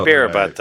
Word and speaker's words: Ibarrabutte: My name was Ibera Butt because there's an area in the game called, it's spelled this way, IBARRABUTTE. That's Ibarra butt. – Sure. It's got to be a joke, right? – I'm sure it's supposed to be Ibarra butte.